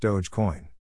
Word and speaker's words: Dogecoin: The name Dogecoin has become a source of controversies among users over the years, though co-founder Jackson Palmer says it is pronounced Dogecoin.